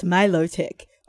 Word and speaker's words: Milotic: Because Milotic is one of the most incorrectly pronounced Pokemon.